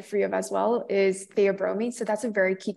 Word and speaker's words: theobromine: Theobromine.